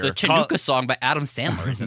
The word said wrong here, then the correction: Hanukkah: The Hanukkah song? – Oh, is that what it is?